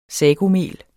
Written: sagomel

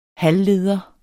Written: halvleder

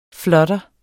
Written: flutter

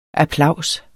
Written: applaus

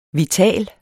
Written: vital

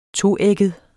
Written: toægget

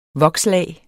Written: vokslag

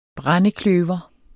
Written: brændekløver

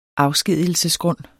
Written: afskedigelsesgrund

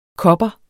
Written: kopper

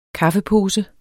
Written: kaffepose

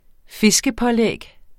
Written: fiskepålæg

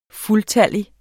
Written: fuldtallig

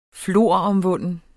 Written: floromvunden

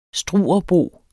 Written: Struerbo

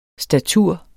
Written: statur

